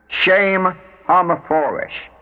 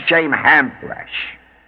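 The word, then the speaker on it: Shem HaMephorash: Shame hamaforesh, not shame hamfresh. Ham is not considered kosher in Hebrew, so it may not be pronounced that way.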